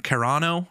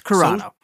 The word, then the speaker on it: Carano: But it says Lucasfilms calls Gina Carano. I can't pronounce her last name. – It's Carano. – Carano. Good.